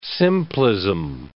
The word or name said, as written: simplism